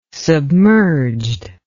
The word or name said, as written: submerged